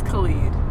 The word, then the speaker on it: Khalid: Khalid? – I think it's Khalid.